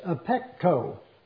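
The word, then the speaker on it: apekko (ἀπέχω): The Greek word is apekko, A-P-E-K-O is the way you pronounce it. It is the word A-P-E-C-H-O.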